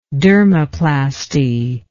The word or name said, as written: dermoplasty